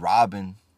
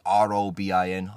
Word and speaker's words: Robin: That makes me feel like, you know, I said Robin, like R O B I N, Robin.